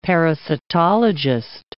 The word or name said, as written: parasitologist